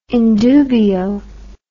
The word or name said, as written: in dubio